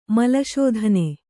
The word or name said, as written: ಮಲಶೋಧನೆ